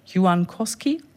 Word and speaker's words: Juankoski: You originally come from a small Finnish town called, excuse my Finnish, Juankoski? How do you say it? – Perfect.